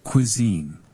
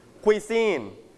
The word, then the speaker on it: cuisine: Cuisine. – Okay. Your turn. 1, 2, go. You stress on first or second syllable. Second นะครับเน้นที่คำว่า seen นะครับ seen Q can you pronounce the word cuisine นะคำนี้ออกเสียงว่า k everybody repeat after me นักเรียนปลายทางด้วยกัน k w e q u i k w e e เป็น qui cuisine